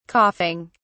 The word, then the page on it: coughing: British English